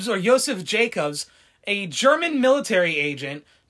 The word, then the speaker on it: Josef: Another theory comes from an MI5 declassified file about Joseph Jacobs, or Joseph Jacobs, because it's spelled J-O-S-E-F, so I'm assuming Joseph.